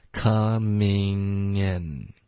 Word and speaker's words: coming: OK，然后这个 in 的这个的这个音呢是一个后鼻音，大家注意不要发成一个顶舌尖的 n。 OK， coming in 这样当然那种发音也有，可是这个时刻我们模仿导演的说法，他说的是后鼻音的方式 coming in Thanks for coming in.